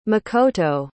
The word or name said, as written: makoto